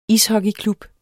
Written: ishockeyklub

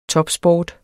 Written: topsport

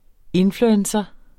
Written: influencer

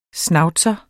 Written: schnauzer

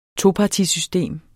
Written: topartisystem

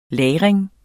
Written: lagring

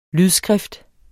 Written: lydskrift

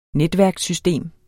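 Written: NETVÆRKER

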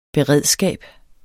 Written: beredskab

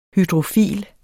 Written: hydrofil

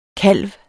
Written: kalv

